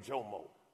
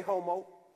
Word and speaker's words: Jomo: Listen, my name Jomo. J-O-M-O. You know how long my name get butchered? Imagine being in Spanish class. Hey, homo.